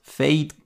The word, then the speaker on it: Feid: Das ist der kolumbianische Rapper und Sänger Feid, geschrieben F-I-F-E-I-D. Ich hoffe, ich habe es richtig ausgesprochen, aber ich glaube, der wird Feid ausgesprochen, der wirklich dieses Jahr und schon letztes Jahr Hit einen Hit nach dem anderen herausgeballert hat.